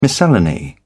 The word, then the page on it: miscellany: British English